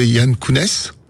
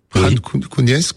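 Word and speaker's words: Khan Younès: Il est dans le gouvernorat de Yann Kounes. Khan Kounes, ça dépend comment on veut le prononcer.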